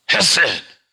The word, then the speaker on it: hesed: And it's the word hesed. You got to say it like that. You know what? If you say it like that, it'll help you remember it. H-E-S-E-D. Hesed. That's the way the Hebrew people say it. That's the way the Jewish people say it.